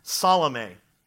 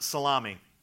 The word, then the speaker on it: Salome: Also present was the sister of Jesus' mother, Salome. It is not pronounced salami.